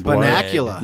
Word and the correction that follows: Bunnicula: Bunnicula. – Yeah. I pronounced it wrong. – Bunnicula. That's right, though.